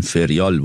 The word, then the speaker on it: فریال: خب بچه ها من میگن که تلفظ صحیح اسمشون فریال بوده که من عذرخواهی میکنم.